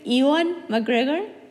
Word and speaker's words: Ewan McGregor: Este, no, no me gusta, amo el actor, pero me choca porque no sé cómo pronunciar su nombre. Y luego siempre tiene que el pobre cuando va a interviews de qué decir, de qué se pronuncia. Entonces, si lo, si lo estoy diciendo mal, una disculpa. pero creo que es Ewan McGregor. Ewan. Ewan.